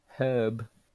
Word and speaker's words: herb: Herb. – Well, herb is a name. Herb is how it's pronounced. The H is silent.